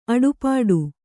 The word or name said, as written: ಅಡೆಪಟ್ಟಿ